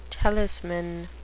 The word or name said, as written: talisman